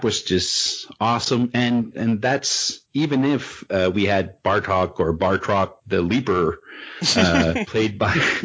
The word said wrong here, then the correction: Batroc: The one French character in the movie, and you can't even say his name right. It's Batroc.